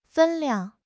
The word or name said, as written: 分量